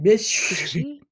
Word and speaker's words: Bysshe: Bysshe. – Bysshe. It's B-Y-S-S-H-E.